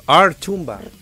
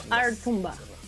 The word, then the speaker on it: Artumba: Es que no sé cómo se pronuncia, porque es como R Tumba. – Artumba. – Artumba.